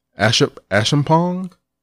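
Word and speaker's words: Acheampong: I can't pronounce her last name. It's A-C-H-E-A-M-P-O-N-G. Ashampong? – Yeah.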